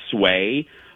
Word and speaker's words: Suay: And the third mask, and I'm going to spell this, I believe it's pronounced Sway, S-U-A-Y. Wouldn't you say, Lex? Wouldn't you say that's what it is? Sway? – Yeah. Yep. – Yeah. S-U-A-Y, and you can find them at Sway Sew Shop.